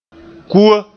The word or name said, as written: kua